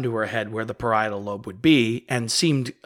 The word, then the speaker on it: parietal: I asked for the correct pronunciation of that from my fiance who works uh, who works in brain science, and it's the parietal lobe.